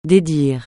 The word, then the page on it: dédire: French